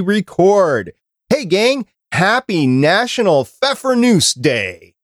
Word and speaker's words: Pfeffernuss: So Pfeffernuss is seen pretty commonly in stores and stuff, and they're really, really good.